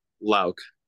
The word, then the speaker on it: Louck: Did I pronounce that right? – Yes, it is Louck. Louck? – Okay, perfect. All Louck. Right. So, sorry, what was that? – Louck.